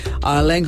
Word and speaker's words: Erling: And, or should we say in his language, Erling. I'm really making an effort to remember how to pronounce it. Erling Haaland.